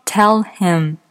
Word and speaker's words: tell him: 再举一个例子，字母 H 的发音在有些情况下会被弱化击穿，比如说 “tell him”，告诉他的意思，当它连起来读的时候，“him” 的 H 的音就会被击穿。连起来读，tell them，tell them，这个呢，可以把它想象成像滴水石穿一样，水滴开始打在石头上的时候，会发出滴答滴答的声音。